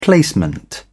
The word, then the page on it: placement: British English